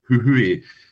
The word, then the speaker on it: Jujuy: Multiple attempts and variations have been made in the name of this place, which is spelt J-U-J-U-Y, but I'm reliably informed, or hopefully reliably informed, it's Huhue.